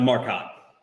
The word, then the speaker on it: Marcotte: The Marcotte.